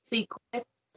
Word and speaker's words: Sssecret: My name is Secret, pronounced Secret. So it's Secret, S-S-S-E-C-R-E-T, triple X.